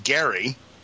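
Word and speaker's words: Gerry: This goes back to, I'm just happened to look up Elbridge, which his name was actually pronounced Gary.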